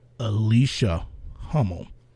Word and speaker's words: Alicia Hummel: Alicia Hummel.